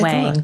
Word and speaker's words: Wang: And when I said Wong, I'm so sorry. – Well, it's the correct pronunciation. You use the correct pronunciation, but I say Wang because everyone will spell it wrong.